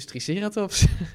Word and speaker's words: Triceratops: Ja, nou, ook daar gaan we het volgende week over hebben, want ik heb natuurlijk gevraagd hoe je dat uitspreekt. – En? – Nou, dat is dus Triceratops. – Maar dat is dan de Engelse klemtoon.